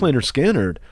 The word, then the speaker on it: Lynyrd Skynyrd: One is, and I'm a huge Lynyrd Skynyrd fan, pronounced Lynyrd Skynyrd.